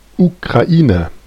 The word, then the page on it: Ukraine: /juːˈkɹeɪn/; /juˈkɹeɪn/; /ˈjuˌkɹeɪn/